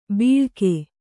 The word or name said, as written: ಬಿಳ್ಕೆ